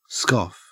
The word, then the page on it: scoff: Australian English